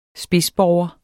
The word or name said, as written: spidsborger